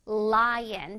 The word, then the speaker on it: lion: 자, 여기에 L 발음을 주의해야 돼. 자, L의 소리의 강세를 둬볼게요. 그냥 라이언이 아니란 뜻이야. 자, lion 할 때 L 소리를 아주 자신감 있게, 사자가 마치 어흥 하듯이 L 소리를 강하게 내줄 거야.